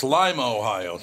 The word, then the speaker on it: Lima: It's Lima, Ohio. It's not Lima.